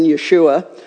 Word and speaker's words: Yeshua: And although in the first century there were many Jewish boys with the name Joshua or Jesus, or it really would have been pronounced back then Yeshua, only the child developing within Mary's womb would live up to his name by saving his people from their sins.